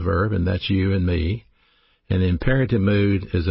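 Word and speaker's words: me (μή): Along with the word judge, we have a Greek particle pronounced me, M-E.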